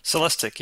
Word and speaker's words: Celestiq: And correct me if I'm pronouncing this wrong. It's the Celestiq? – Celestiq, yes.